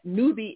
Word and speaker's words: Newby: Newby is spelled N-E-W-B-Y.